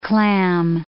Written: clam

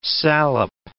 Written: salep

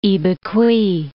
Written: Ibicui

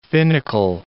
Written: finical

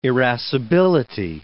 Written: irascibility

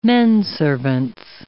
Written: menservants

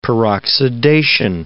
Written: peroxidation

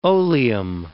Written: oleum

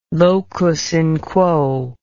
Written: locus in quo

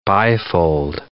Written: bifold

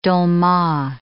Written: dolma

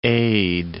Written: aide

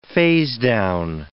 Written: phasedown

